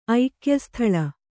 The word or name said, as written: ಐಕ್ಯಸ್ಥಲ